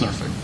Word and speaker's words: Norfolk: Most people pronounce it Norfolk, except if you live there. – Yeah. It's Norfolk.